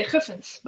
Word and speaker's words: Guffens: Did I say it right? Guffins. – That's okay. That's the English version. I would say Guffins, but...